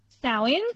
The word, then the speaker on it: Samhain: But then we move, obviously, from the Romans to Samhain, which is, it's spelt Samhain, but it's pronounced Samhain.